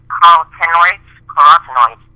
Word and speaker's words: carotenoids: And the Bixans are very powerful carotenoids, like the foods that are found, the color that's found, or actually the element that's found in carrots.